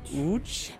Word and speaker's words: Łódź: Jak to się właściwie wymawia? – Łódź.